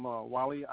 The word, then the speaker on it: Wali: Yes, I'm Wally Ayu Bilal. I'm the imam out of the Philadelphia area. My name is spelt W-A-L-I, middle name A-Y-Y-U-B, and last name B as in brother, I-L-A-L.